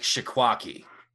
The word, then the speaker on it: Cheesequake: There's a place in New Jersey called like Shikwaki. That's how you pronounce it. But the way that it's spelled on the signs is Cheesequake.